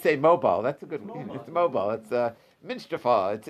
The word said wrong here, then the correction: Minashtifa: It's pronounced Minashtifa. – Minashtifa. Okay. Minashtifa.